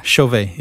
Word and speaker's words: Chauvet: Yeah, Chauvet, yeah.